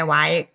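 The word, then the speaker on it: Wye: Bei Y, W-Y-E geschrieben, aber Y ausgesprochen,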